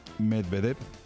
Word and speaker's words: Medvedev: Anoche, tras un partido que duró 4 horas y 51 minutos, casi 5 horas, contra el ruso Medvedev, dicho así en castellano, porque si hay que pronunciarlo en ruso hay que meter una I por medio y es un poquito más complicado.